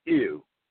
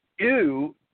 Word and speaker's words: ewe: In English, the English language, sheep is called an ewe. – Okay. – Ewe is E-W-E. That's how we spell it in English.